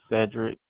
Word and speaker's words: Cedric: My first name is Cedric, C-E-D-R-I-C. My last name is Henley, H-E-N-L-E-Y.